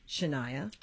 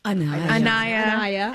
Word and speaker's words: Anaya: So how would you describe your characters, Shania? – Anaya.